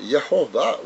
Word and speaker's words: Yehovah: Exodus 3:15 says that Yehovah will be his name forever. And we know that's the pronunciation now.